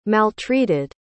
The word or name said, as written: maltreated